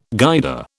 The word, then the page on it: Guider: British English